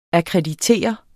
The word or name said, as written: AKKREDITER